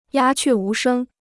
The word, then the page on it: 鸦雀无声: yā què wú shēng